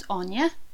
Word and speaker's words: Áine: So I was like, my name's Áine, pronounced it for them.